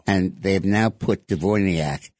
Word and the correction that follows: Dvornikov: am I pronouncing it right? We got to learn that name. Dvornikov. They have now put Dvornikov in charge of the Russian war effort.